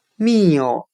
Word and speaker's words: mill: 今天我们来学习单词 “meal”，m i l l，表示磨坊的含义。我们可以用谐音法来记忆这个单词 “meal”，它的发音很像汉语的“ 米欧”，大米的“ 米”，“ 欧” 为语气词。